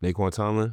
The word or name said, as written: Naeqwan Tomlin